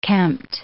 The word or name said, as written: kempt